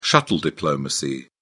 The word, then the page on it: shuttle diplomacy: British English